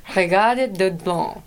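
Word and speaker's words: Reggatta de Blanc: Regatta de Blanc.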